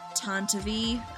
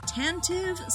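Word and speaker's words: Tantive IV: Okay, so I went through like 8 different possible pronunciations for the name of Bail Organa's ship. Some sources say Tantivy, which is what I ended up going with. Some sources say Tantive, some sources say Tantive, but Tantivy seems to be the one that has the sort of the phonetics written out on Wikipedia, so that's what we're going with.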